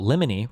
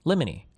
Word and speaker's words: Limony: Oh, Limony rhymes with Jiminy. Limony.